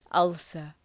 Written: ulcer

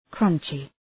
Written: crunchy

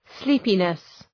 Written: sleepiness

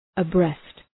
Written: abreast

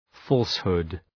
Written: falsehood

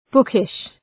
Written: bookish

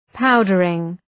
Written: powdering

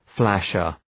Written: flasher

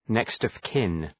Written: next-of-kin

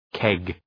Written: keg

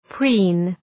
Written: preen

